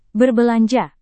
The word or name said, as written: berbelanja